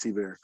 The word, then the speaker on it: Thibert: Art T-Bear was a, it's spelled Thibert, but I believe it's pronounced T-Bear. – Correct.